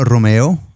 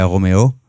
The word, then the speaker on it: Roméo: Roméo, está ¿sí? bien, en español, en francés sería Roméo, uh-huh.